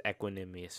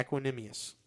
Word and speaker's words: Equanimeous: So, And just to go back on that real quick, I think it's Equanimeous St. Brown. – That is the correct pronunciation. Yeah, Equanimeous St. Yeah. Brown. I'm just sitting here going equilateral.